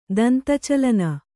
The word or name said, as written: ದಂತಚಲನ